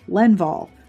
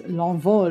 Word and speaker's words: L'Envol: Now, if you're going to just look at it and say it like in American English, it would be L'Envol, but that is not right. It is L'Envol, and I'm going to try really hard not to screw that up.